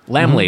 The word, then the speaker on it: Laemmle: Laemmle, mm-hmm.